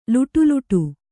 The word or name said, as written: ಲೊಡಲೊಡ